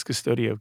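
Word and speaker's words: Qustodio: We used Custodio. – Okay. – But have you seen it? It's spelled Q-U. Oh, goodness. Now, Q-U-O-S. It sounds like custodian, but it's Custodio.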